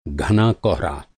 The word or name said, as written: कोहरा